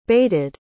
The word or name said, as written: baited